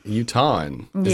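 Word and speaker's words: Utahn: Utahan.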